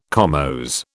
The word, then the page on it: comose: British English